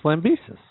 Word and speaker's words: Lambesis: Lambesis? That's how you said it when we had him on the show. – It's Lambesis. – Okay. I'm going with your original pronunciation from 2009.